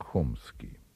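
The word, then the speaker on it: Chomsky: Американец Ноам Чомский, в России его фамилия произносится как Хомский, один из самых замечательных лингвистов современности и ветеран левого пессимизма.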